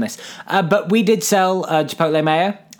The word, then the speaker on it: Chipotle: you mentioned the word Chipotle there, which is the correct pronunciation, of course.